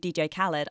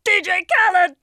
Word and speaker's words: Khaled: I would like to pick you up on your pronunciation of DJ Khaled. I think it's actually pronounced DJ Khaled.